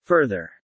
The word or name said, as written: further